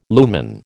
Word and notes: lumen: American English

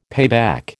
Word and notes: payback: American English

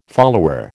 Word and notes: follower: American English

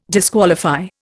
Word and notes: disqualify: Indian

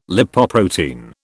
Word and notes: Lipoprotein: British English

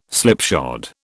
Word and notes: slipshod: British English

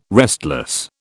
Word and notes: restless: British English

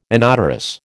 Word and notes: inodorous: American English

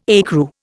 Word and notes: ecru: Indian

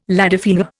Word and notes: ladyfinger: Indian

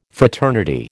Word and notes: fraternity: American English

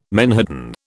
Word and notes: menhaden: British English

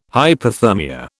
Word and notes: hyperthermia: British English

